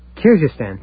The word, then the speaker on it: Kyrgyzstan: I'm afraid I'm not pronouncing it right. Kyrgyzstan. Yeah, you can do it that way.